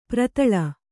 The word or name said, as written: ಪೆರೆದಲೆ